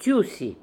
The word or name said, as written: Toosy